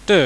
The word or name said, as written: ty